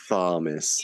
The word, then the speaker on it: Thomas: You know, it has an H in it. You don't even pronounce it. What a stupid name. – Thomas.